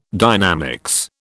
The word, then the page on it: dynamics: British English